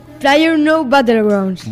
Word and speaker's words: PlayerUnknown's Battlegrounds: Pero resulta que en aquel momento estaba empezando a explotar un juego, a ver si lo digo bien, PlayerUnknown's Battlegrounds o algo así. A ver, dímelo tú. – PlayerUnknown's Battlegrounds.